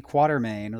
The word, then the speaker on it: Quatermain: Yeah, the proper way would be Quatermain. Like it rhymes with Watermain.